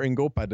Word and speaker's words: Ngoepe: Gift Ingope. – Or Ingopa, depending on who's pronouncing it, but yeah.